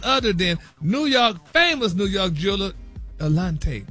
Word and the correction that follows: Eliante: Eliante. – Are they how you pronounce it?